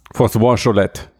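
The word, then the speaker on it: François Chollet: Und wenn man sich da an den KI-Forscher, wie spricht man den aus? – François Cholet. – Ja, genau.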